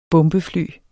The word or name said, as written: bombefly